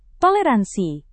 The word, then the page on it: toleransi: Indonesian